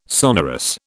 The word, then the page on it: sonorous: British English